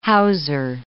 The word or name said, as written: houser